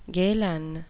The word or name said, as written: Galen